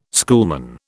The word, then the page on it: schoolman: British English